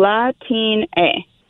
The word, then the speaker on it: Latine: Latin A.